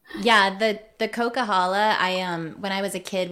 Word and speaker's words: Coquihalla: Coquihalla? – Yes.